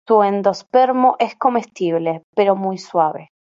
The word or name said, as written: comestible